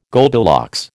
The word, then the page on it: Goldilocks: American English